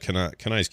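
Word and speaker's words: Kanai: How do you say it? – Canai's cube. Yeah.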